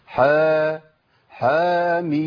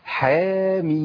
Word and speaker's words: حم: يبقى ما قلتش حا حاميم، لا ده خطا، هي ايه؟ حاميم، ده النطق الصحيح ليها.